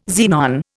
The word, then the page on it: xenon: Indian